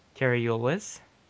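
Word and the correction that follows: Cary Elwes: Carrie Elwes. Oh, okay. – Yes! – Sorry, you pronounced it oddly.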